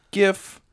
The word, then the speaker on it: GIF: I don't care if the guy who actually created it calls it a GIF. It's short for graphics. And that doesn't start with a hard J, you fucking idiot.